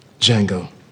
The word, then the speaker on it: Django: Django. Can you spell it? D-J A-N-G-O. The D is silent.